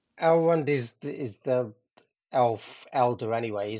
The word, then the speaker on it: Elrond: Elrond.